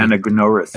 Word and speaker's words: anagnorisis: But as you said, there's like one of the themes of the Odyssey is recognition, agnorasis. I think that agnorasis is how you pronounce it. – Anagnorisis. – Anagnorisis. Yeah, I'm saying it how we say it out here in Oklahoma.